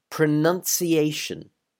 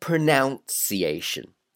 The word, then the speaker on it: pronunciation: It is pronunciation. Pronunciation. Not pronunciation. Now, the verb is to pronounce, but the noun is pronunciation.